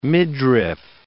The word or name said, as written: midriff